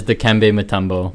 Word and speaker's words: Dikembe Mutombo: The correct pronunciation is Dikembe Mutombo.